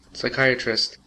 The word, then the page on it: psychiatrist: American English